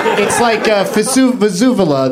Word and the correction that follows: vuvuzela: the horn at the. – Vuvuzela.